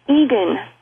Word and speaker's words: Eagan: My maiden name, Egan, which is spelled E-A-G-A-N, and then Cox, C-O-X.